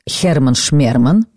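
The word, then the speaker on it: Herman Schmerman: Это был балет с таким странным названием, которое произносится следующим образом: «Херман Шмерман».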